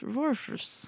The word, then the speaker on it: Warchus: Eh, den er lagd av Matthew Warchus Warchus. – Ti av ti for uttale der.